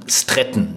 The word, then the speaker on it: Stratton: von Regina Stretten, Stratton Entschuldigung, ist. – Sehr deutsch ausgesprochen, ne? Stratton.